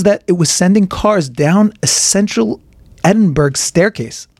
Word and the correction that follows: Edinburgh: It's pronounced Edinburgh. Is it really? It is. – Edinburgh? – Mm hmm. Like that? No, no, yeah, well, yes, but nothing to do with with bra, And when I saw this article, I, you know, I was like, it kind of serves Google right a little bit.